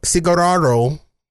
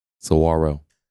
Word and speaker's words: saguaro: we have a a cigar, cigar, cigar, cigararo. How do you say it? Saguaro.